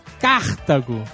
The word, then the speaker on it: Cartago: Durante todo o Nerdcast, os integrantes pronunciaram o nome da cidade de Aníbal como se fosse uma proparoxítona. – Olha aí, sabia. Não era, viu? – Cartago. No entanto, se a ênfase estivesse na primeira sílaba, como vocês falaram, a palavra seria acentuada. O nome da cidade é Cartago.